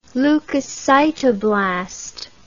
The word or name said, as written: leukocytoblast